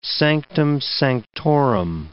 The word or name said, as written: sanctum sanctorum